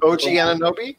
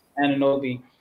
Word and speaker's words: Anunoby: OG Anunoby? – Anunoby.